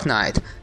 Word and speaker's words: Knight: The Dark Knight 这个 night 就是夜晚的那个 night，前面加一个 k 就变成骑士的意思，发音是一样的。